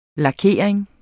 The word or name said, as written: lakering